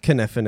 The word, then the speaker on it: Kniffen: The K is silent, I'm assuming, right? – That's right. – All right. – Like sweet. Knife. – Like knife. – But you can go with Kniffen if you want.